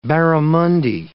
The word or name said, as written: barramundi